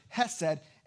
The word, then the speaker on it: hesed: Steadfast love, it's a, a word that in the Hebrew you would pronounce it hesed, and steadfast love is a good way to try to get, the, get at it but it's a covenant word.